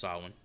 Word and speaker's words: Samhain: On the chalkboard, written in blood, is the word Samhain, as Loomis puts it. Obviously pronounced Samhain.